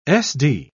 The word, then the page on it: sd: British English